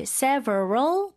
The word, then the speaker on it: several: 근데, 미국식 발음으로는 사실 several 보다는 이음절로 several.